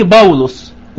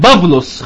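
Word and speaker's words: باولوس (Παῦλος): هيلاقيها قصادها باليوناني باولوس وتتنطق باليوناني الجديد بافلوس، خلي بالكم لكن انا بتكلم باليوناني القديم اللي هو ايه مكتوب بيه العهد الجديد اللي هو موجود في المخطوطات القديمة للانجيل باولوس، عشان تعرفوا ان حتى لو كان اسمه كلمة باولوس يا فيصل قريبة جدا من ايه من باولوس، صح؟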